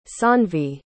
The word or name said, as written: saanvi